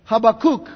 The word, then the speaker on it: Habakkuk: And we must remember what is said in Habakkuk Habakkuk is, that's the best way to pronounce that word.